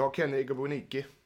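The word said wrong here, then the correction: Igboananike: Om jag uttalar rätt. – Ja, det Nej, det gjorde du inte. Igbuananike.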